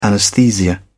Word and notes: anesthesia: British English

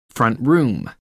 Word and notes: front room: American English

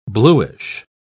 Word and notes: blueish: British English

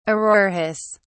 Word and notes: auroras: British English

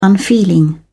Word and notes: unfeeling: British English

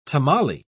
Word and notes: tamale: British English

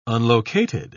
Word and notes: unlocated: British English

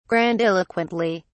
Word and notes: grandiloquently: British English

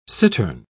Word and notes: cittern: British English

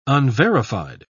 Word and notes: unverified: British English

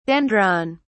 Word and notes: dendron: British English

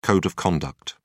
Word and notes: code of conduct: British English

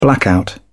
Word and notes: blackout: British English

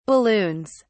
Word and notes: balloons: British English

